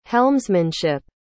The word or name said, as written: helmsmanship